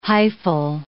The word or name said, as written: hyphal